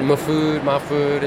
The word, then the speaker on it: Mahfood: Either pronunciation, Mahfood. It's not really our last name anyway, so it doesn't matter.